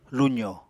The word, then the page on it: luzo: Apatani